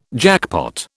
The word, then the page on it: jackpot: British English